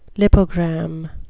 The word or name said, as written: lipogram